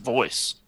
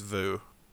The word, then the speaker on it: VOUS: V-O-U-S? – Yeah. – Is it pronounced Vu? I've always heard it pronounced Voice. – I'm pretty sure it's Vu.